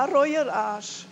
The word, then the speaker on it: Reueraasch: Habe ich es richtig ausgesprochen? – Ja, Reueraasch.